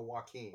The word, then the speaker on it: Joaquin: Jaquan. Is that his name? Jaquan Phoenix? – Oh, Joaquin. – Joaquin Phoenix. Yeah, I said his name wrong.